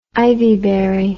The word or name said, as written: ivyberry